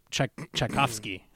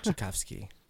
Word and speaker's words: Tchaikovsky: Tchaikovsky. – Tchaikovsky.